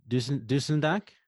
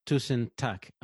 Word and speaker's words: Tusen takk: Tusen takk? – Tusen takk. – Tusen takk. – Tusen takk. – Yeah. Tusen takk. Oh, takk. I think I've heard. Yeah. Tusen takk.